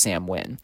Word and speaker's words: Samhain: It's actually spelled Samhain, but it's pronounced Samhain.